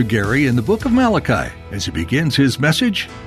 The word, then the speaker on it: Malachi: Malachi's name is pronounced in Hebrew, Malachi.